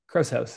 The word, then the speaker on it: Krause House: Yeah, Krause House.